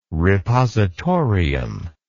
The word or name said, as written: repositorium